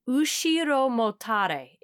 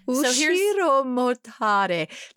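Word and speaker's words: ushiro-motare: But that meant that Hidenoumi won by something called an ushiro motare. If yes. I'm saying that correctly. – You nailed it.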